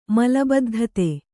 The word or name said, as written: ಮಲಬದ್ಧತೆ